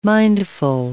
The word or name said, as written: mindful